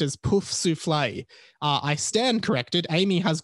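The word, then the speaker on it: Poufsouffle: Hey, so the correct French pronunciation of the Hogwarts houses, we have Gryffindor, Ravenclaw, Sort d'Aigle, Hufflepuff, Pouf Souffle, and Slytherin, which is Serpentard.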